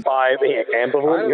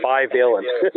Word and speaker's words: bivalent: Is it bivalent? Is that the pronunciation? – Ambivalent? You almost feel like saying, but it's bivalent.